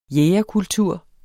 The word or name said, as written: jægerkultur